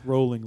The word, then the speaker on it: Rowling: It's pronounced rolling like bowling.